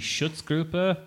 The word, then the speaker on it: Schutzgruppe: You had the Schutzgruppe in 2005. – You actually pronounced that right. Good job.